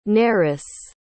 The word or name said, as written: Nerice